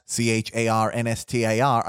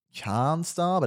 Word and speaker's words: Charnstar: No, but I've got a soft R, so a lot of people think like it's Charnstar, but it's Charnstar.